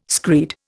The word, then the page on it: screed: Indian